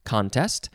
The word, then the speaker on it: contest: The first word is the second form of contest, and it is pronounced with the emphasis on the first syllable, contest.